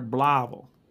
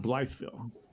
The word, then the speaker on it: Blytheville: And just for the record, it's pronounced, if you're from there, Blavel, not Blytheville. Blavel.